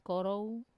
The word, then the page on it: koro: Wamesa